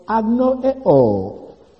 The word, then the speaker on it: agnoeo: Well, the word ignorance here is the Greek word agnoeo. Agnoeo. It's spelled A-G-N-O-E-O.